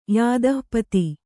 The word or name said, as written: ಯೂಥಪತಿ